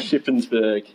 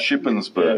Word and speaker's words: Shippensburg: Say that slowly. How do we say it again? – Shippensburg, yeah. – Shippensburg. – Yeah, we keep the P's in there, that's for sure.